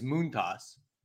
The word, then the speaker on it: Montas: Second off, I think it came out the other day that he actually said he prefers it, or the actual pronunciation is Montas.